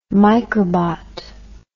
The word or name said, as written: microbot